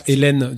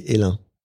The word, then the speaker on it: Hélin: Alors effectivement, quand on lit la bouteille, on pense que c'est Hélène Durand. – Eh ben oui, c'est un homme. Désolé, c'est son prénom s'écrit sans e, donc c'est Hélin.